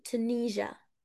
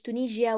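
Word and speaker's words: Tunisia: طيب تونس يعني تونيجا، تونيجا مش تونيجيا ولا تونيسيا ولا كده، تونيجا تونيجا تونيجا. ال S I A دي بتنطق جا، تونيجا تونيجا خلاص.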